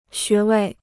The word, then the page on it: 穴位: xué wèi